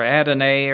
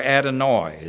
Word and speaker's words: Adonai: This is still some of Schaff Herzog's encyclopedia, and it's saying that was Adonai, A-D-O-N-A-I, 136. Adonai or Adonai or Adonoi is ways that it shows pronounced in Hebrew. But I think we normally refer to it as Adonai because of that song that we have sung and heard sung.